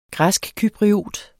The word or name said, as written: græskcypriot